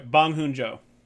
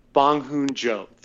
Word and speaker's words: Bong Joon-ho: Bong Joon-ho. – Bong Joon-ho.